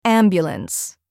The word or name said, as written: ambulance